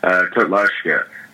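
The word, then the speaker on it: Kotlářská: And so, so when you came over and you started with the club there, the men's club, is it Kotlarka, or how do I say it? – Yeah, Kotlarska. Kotlarska. There's that little thing over the R.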